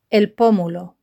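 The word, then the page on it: los pomulos: Spanish (Spain)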